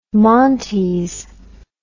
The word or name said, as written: montes